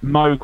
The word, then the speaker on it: Moog: Moog was supposed to rhyme with Vogue.